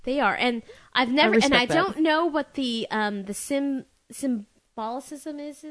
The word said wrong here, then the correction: symbolism: Symbolism, thank you.